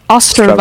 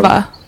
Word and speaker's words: Ostrava: Ostrava. – Ostrava. – Oh, Ostrava. – What were you My saying? Bad. Yeah, Sorry. you're doing it in German. Il- Ilstrava. Ostrava.